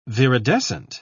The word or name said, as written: viridescent